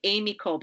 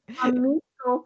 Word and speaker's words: Amico: She is also a successful author, artist, musician, and the creator of, I want to say Amy Cole, but that's not right. How do you say it? – Well, I say Amico, but I don't know how to say it properly. – Amico cards, which means friend.